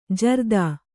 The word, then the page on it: ಜೋರತ್ತು: jōrattu